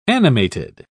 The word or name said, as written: animated